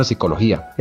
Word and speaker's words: psicología: Lo mismo sucede con el término psicología, el cual se puede escribir perfectamente con o sin P. Esto además porque la P delante de psicología no se pronuncia.